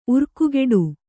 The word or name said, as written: ಉರ್ಕುಗೆಡು